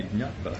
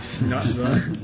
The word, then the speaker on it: jnatva: That should be jnatva. – Yeah. – I suppose here. – How do you say that? – That would be jnatva.